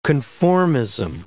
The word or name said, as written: conformism